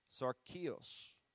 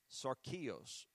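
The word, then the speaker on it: sarkikos: I'm going to spell it for you. S-A-R-K-I-K-O-S. S-A-R-K-I-K-O-S. Sarkios. Sarkios. 45:59. Sarkios.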